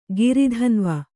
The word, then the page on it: ಗಿರಿಧನ್ವ: kannada